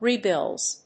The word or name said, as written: rebills